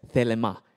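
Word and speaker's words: thelema (θέλημα): So will in the Greek is actually pronounced as thelema, T H E L E M A.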